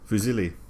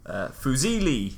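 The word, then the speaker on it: Fusilli: How to pronounce Fusili. – As in the pasta. – Yeah, and it is... Foo, as in F-double-O.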